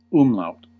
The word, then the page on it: umlaut: American English